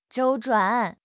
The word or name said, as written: 周转